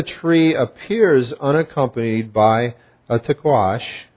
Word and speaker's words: taqsh: But it's called a tkawash. Tkawash. It's spelled T-A-Q-S-H.